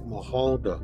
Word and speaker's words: Mahulda: Her name is M A H U L D A. It's like Mahalda.